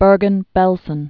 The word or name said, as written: Bergen-Belsen